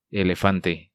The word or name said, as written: Elefante